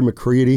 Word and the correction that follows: McCreary: They got him for McCreary.